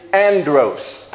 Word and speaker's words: andros: In verse 3, the Greek word for man is andros. In verse 3, the Greek word for man is andros.